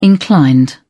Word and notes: inclined: British English

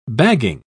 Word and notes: bagging: British English

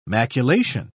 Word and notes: maculation: British English

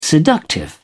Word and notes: seductive: British English

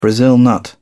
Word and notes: brazil nut: British English

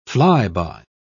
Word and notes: fly-by: British English

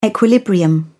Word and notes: equilibrium: British English

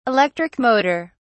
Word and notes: electric motor: British English

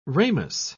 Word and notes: ramous: British English

